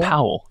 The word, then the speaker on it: Powell: Do we say Powell? – Powell. – Powell. Powell is the English pronunciation.